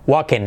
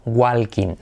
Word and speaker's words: walking: Cuidado la W porque no va a ser una G, gu, sino u, wa, wa, wa, walking. La L además no se pronuncia y eso. Cuidado, la W es letra semiclave porque tendemos a decir gua, walking. No, wa, walking.